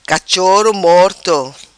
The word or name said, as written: Cachoro Morto